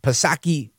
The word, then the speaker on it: Psaki: DeSantis fires at them, Psaki with the silent P, she fires back at DeSantis.